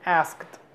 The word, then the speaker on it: asked: Este verbo se pronuncia asked.